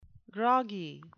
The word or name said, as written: groggy